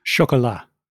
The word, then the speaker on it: Chocolat: Now, I noticed you pronounce it Chocolat. What's the official pronunciation? – Chocolat. – Okay, good. So I forget my point now that I got the pronunciation right, but we certainly see that anger in that movie, right, underpinning it.